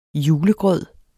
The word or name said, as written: julegrød